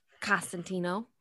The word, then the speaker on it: Cosentino: Cosentino.